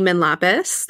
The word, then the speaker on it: Manlapas: Yeah, you guys can find me on TikTok under my full government name, Amy Manlapis. That's also my Instagram. And Amy is spelled A-M-Y. My last name is M-A-N-L-A-P-A-S.